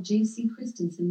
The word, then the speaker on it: J.C. Christensen: Jens Christian Christensen, most often called J.C. Christensen with the J pronounced as an I, was a Danish politician.